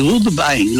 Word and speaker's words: Woodbine: y es el hipódromo de Woodbine. Lo dije bien, Godvine. – Qué bien, favorito, qué inglés.